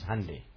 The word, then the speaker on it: Hyundai: I actually heard it on a program, and that was when the pronunciation was asked, and it was said like Sunday is Hyundai.